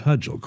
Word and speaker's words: Hughel: Her last name is spelled H-U-G-H-E-L, but she pronounces it Hudgel, correct? – Correct.